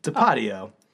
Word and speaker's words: Tapatio: faster than 400 Palominos gets her hot like Tapatio or Tapatia. How do you say it? I can't even remember now. – Tapatio. – It's a hot sauce. Tapatio is a damn hot sauce.